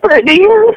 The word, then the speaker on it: Brittany: Brittany.